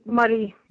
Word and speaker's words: Moray: Well, Moray. – Morayshire, yeah. Morayshire, what's going on there? – Well, we don't know. Last week, there started to be some murmurings in the news about an outbreak in Moray.